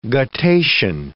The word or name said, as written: guttation